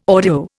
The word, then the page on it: audio: Indian